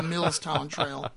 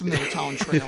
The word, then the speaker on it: Mill Towns Trail: According to the information that Ben Martin. – It's Milltown Trail. – It's Milltown Trail. I've been saying it wrong, too.